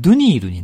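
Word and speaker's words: Dunhill: これ置いてたんですけども、ダンヒルも、 H、間にね、 H が、ダンヒルで HILL ってあるんですけど、これもね、読まないんです。だから、ヒルって読まないですよね。だからドゥニールになります。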